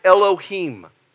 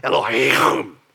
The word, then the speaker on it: Elohim: The word here for God is Elohim. If you want to really say it correctly in the Hebrew, Elohim, okay?